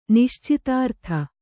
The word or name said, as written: ನಿಶ್ಛಿದ್ರತೆ